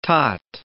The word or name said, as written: tot